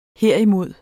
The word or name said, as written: herimod